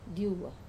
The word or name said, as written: diuwa